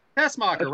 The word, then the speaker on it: Tessmacher: The participants are Velvet Sky, Gail Kim, Miss, how do you pronounce that? Tessmacher? I didn't watch TNA. – Tessmacher, right? – Yeah.